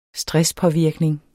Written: stresspåvirkning

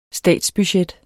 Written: statsbudget

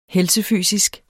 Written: helsefysisk

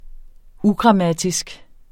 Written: ugrammatisk

